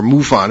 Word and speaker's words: MUFON: Mark is also the National Director of Photo and Video Analysis for the Mutual UFO Network, MUFON, or MUFON as it's sometimes pronounced.